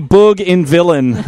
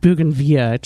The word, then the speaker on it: bougainvillea: It'll just be Boog in Villain. You know, the Boog Villain. – It's actually pronounced Bougainvillea, just to let you know.